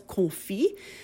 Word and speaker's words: confit: And the recipe is, the first word in it is C-O-N-F-I-T, which the French major in me wants to pronounce correctly as confit, and which perhaps the American in me wants to say confit.